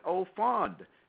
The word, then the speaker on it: au fond: They know it au fond, and that's spelled A-U-F-O-N-D, which means like in depth.